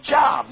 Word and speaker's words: Job: I said, that's not Job. J-O-B spells Job. Nah, I learned that in school. She said, honey, it's pronounced Job.